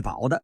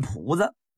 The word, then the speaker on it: 堡: 努尔哈赤在督战时被这炮火击中，受了重伤，撤离到离这个沈阳 40里的爱戴，当时这个地方叫爱戴浦，也管这个叫爱戴堡的。这个浦跟堡啊，它不是多音字，应该啊念这个堡，堡垒，但是东北人呢都管这个字念浦子。